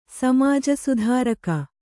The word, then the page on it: ಸಮಾಜಸುಧಾರಕ: samāja sudhāraka